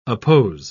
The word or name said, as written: oppose